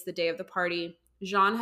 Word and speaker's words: Jeanne: Tamla is invited to a woman named Jean. It's spelled like Jean, J-E-A-N-N-E, okay.